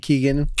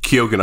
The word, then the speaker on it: Keoghan: How do you pronounce his name? Keegan? – Keoghan, I think his name is.